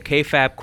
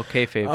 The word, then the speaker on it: kayfabe: The kayfabe corner. – Don't... Well, I guess I'm bringing it back since you pronounced kayfabe incorrectly. – Oh, kayfabe.